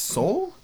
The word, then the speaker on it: Soule: They announced this new creative team of Charles Soule, I believe is how you'd pronounce his name, S-O-U-L-E.